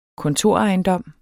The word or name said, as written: kontorejendom